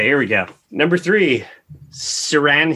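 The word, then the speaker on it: Ciarán: Hines, H-I-N-D-S. – Hines. – Yeah, and then first name is C-I-A-R-A-N. Sirian Hines.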